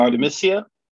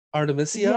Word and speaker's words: Artemisia: Am I saying her name right? Oh, what's up? – Um, Artemisia. – Artemisia. – Yeah, I, right.